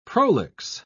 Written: prolix

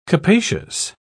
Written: capacious

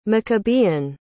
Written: maccabean

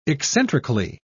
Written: eccentrically

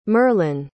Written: merlyn

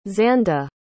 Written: xandy

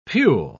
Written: pule

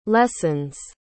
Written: lessens

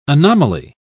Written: anomaly

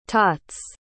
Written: tots

